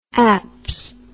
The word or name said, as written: apps